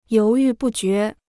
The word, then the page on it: 犹豫不决: yóu yù bù jué